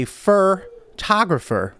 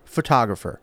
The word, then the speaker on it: photographer: I say fur-tographer, and it's photographer.